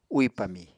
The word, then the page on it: úì pami: Apatani